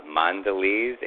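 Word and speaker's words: Mondelēz: Mondelez International. Am I pronouncing that right? – You are.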